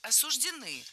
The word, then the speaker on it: осуждены: Правильный вариант – осуждены. Ударение в этом слове ставим на окончание. Вариант «осуждены» допустим, но только в профессиональной речи представителей правоохранительных органов.